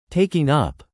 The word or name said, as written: taking up